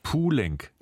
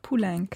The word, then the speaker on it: Poulenc: Francis Poulenc. – Poulenc. Also wie ein U und dann kann man wirklich so wie L-A-E-E-N-K. – Ja, Poulenc.